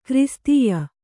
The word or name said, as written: ಕ್ರಿಸ್ತೀಯ